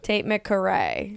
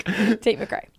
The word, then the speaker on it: Tate McRae: You get stuck on the C. That's the big mistake people make. How would you say Tate McRae? And then say it like that.